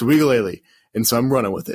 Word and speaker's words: Uiagalelei: I found out it's pronounced Uiagalelei, which is weird, right? – Uiagalelei.